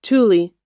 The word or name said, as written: tule